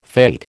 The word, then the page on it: fate: American English